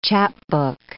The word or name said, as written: chapbook